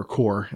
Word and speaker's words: Corps: Contra Rogue Corps. So if you are familiar, or core, as most people pronounce that, if you are familiar with Contra, then you might want to check out this game.